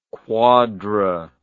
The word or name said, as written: quadra